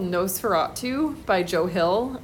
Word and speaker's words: NOS4A2: It's spelled kind of funny, so if you go into our catalog and you're looking for it, it's NOS4A2. So NOS4A2. If you think of it like a license plate, that's how it's set in the book.